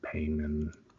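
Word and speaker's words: Paimon: And then, like, if you read up on who Payman was and everything, I think that's the right name, Payman, P-A-E, let me make sure I'm saying it right so I can give him his dues.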